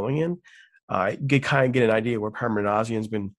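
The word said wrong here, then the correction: Minasian: Did I say that right? – Manassian. Yeah. Manassian.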